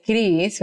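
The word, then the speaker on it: Chriz: And then my nickname is Chris with a Z because, so my family calls me Chris.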